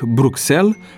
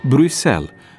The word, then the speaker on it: Bruxelles: Sunt unele situații în care, în numele proprii străine, litera ks nu se pronunță nici ks, nici gz, ci s simplu, ca în cazul capitalei Belgiei, pe care atât de mulți o rostesc Bruxelles, când de fapt pronunția corectă este Bruxelles, sau ca în cazul orașului chinez Xiamen, scris Xianmen, pe care mulți din neștiință chiar așa îl citesc, Xianmen.